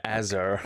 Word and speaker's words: Azure: Yeah, Azure, okay. Like pressure.